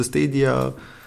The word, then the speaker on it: Stadia: Ei scris Stadia, se pronunță Stadia.